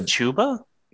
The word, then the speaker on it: Chuba: His name is Chuba. – Yeah, there's one B. – I know, but it just reads so much better as Chuba Hubba. – Chuba rhymes with tuba.